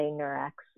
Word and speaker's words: Nurx: But I think over time, we've just really liked the way that it sounds when you say Nurx. And so it's spelled N-U-R-X, so you weren't, there's no right or wrong, Jared. but, you know, we just say it Nurx, so.